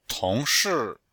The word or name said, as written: tong2shi4